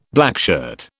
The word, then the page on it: blackshirt: British English